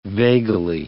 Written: vagally

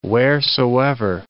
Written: wheresoever

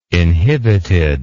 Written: inhibited

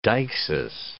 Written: deixis

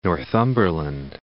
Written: Northumberland

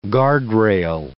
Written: guardrail

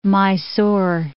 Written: Mysore

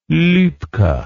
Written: Lubke

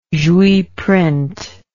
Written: jouy print

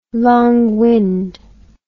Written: long wind

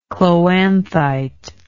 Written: chloanthite

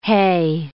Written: hay

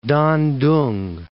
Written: Dandong

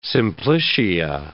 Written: simplicia

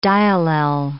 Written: diallel

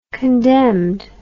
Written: condemned

